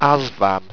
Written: azvan

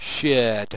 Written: chaerd

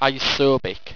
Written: isobic

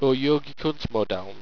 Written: ojogicuntmodeln

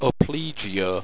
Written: opligeo

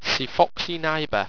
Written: cifoxyniba

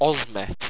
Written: ozmet

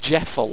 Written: jeffle

